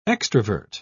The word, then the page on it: extravert: British English